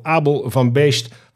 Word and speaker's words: Abel van Beest: Abel van Beest van Forge Hydrogen Racing.